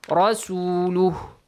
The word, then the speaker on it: رسوله: رَسُولُهُ وَمَنْ يَعْتَصِمْ بِاللَّهِ فَقَدْ هُدِيَ إِلَى صِرَاطٍ مُسْتَقِيمٍ. – Can you pronounce the رسول again? رسوله.